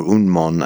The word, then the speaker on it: Unmon: I guess Yunmen is another pronunciation for Unmen, or Unmon, I think is how Nishijima and Cross give it. It's just the difference between the Japanese and the Chinese pronunciations.